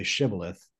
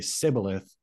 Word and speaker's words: Shibboleth: and the guys in the rival tribe tell him to say Shibboleth. And because the people from his tribe say Sibboleth rather than they can't pronounce the sh.